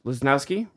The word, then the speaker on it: Lasnoski: But it's great to have you here, Dr. Kent Lasnoski. Lasnoski, very fast.